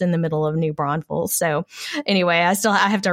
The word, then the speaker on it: New Braunfels: It is German, but nobody I know pronounces it that way. We say New Braunfels, and there's no extra S in the middle.